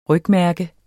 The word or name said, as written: rygmærke